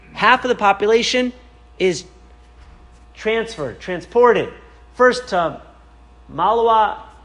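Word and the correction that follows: Mława: which you see up, how do you pronounce it? – Lawa, which is to the west, and then on December 7th to Auschwitz, and on December 10th to Birkenau.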